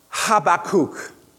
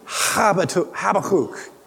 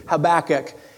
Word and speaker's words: Habakkuk: It's pronounced Habakkuk. Habakkuk. That's the way it's pronounced. Okay? But because that's a little bit difficult for me to continue to refer to it like that, I'm just going to go ahead and use the English pronunciation, Habakkuk, as we read this.